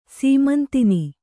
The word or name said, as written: ಸೀಮಂತಿನಿ